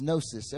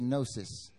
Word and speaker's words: gnosis: The word for knowledge in the Greek is gnosis. Everyone say gnosis. It's spelled G-N-O-S-I-S, right?